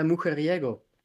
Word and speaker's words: Mujeriego: Mujerigo? – Äh, Mujeriego. Ja, von Ryan Cast äh, Castro.